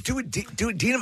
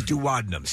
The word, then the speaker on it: duodenum: And by the way, so it's duodenum. I always thought it was duodenum, so that's not the correct way to say it.